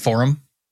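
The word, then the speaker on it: Forem: I think it's pronounced forum, but it's F-O-R-E-M.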